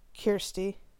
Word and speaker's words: Kirstie: Is it pronounced Kirstie? – Kirstie. I have a hard time.